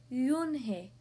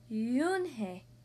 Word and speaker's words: Yoon-hae: Yes, it's pronounced Yoon-hae, and it means grace, Mr. Kim added. – Yoon-hae, Joey said slowly, and this time perfectly.